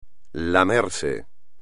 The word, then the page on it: lamerse: espagnol